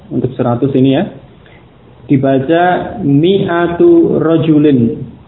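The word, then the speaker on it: mi'atu (مِائَة): Ya, tulisannya walaupun ada alif, tetapi alif yang sesudah mim ini tidak dibaca, tidak diucapkan. Sehingga bacanya cukup mi'atu. Ya, mi'atu pendek ya. Ini bukan ma'atu, juga bukan mi'atu. Karena alif itu tidak dibaca, ya, tertulis tetapi tidak dibaca. Jadi mi'atu. Mi'atu rajulin, 100 orang laki-laki.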